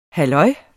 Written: halløj

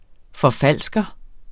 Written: forfalsker

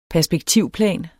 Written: perspektivplan

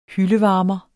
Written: hyldevarmer

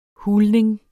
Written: hulning

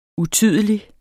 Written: utydelig

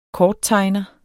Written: korttegner

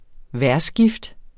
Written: vejrskift

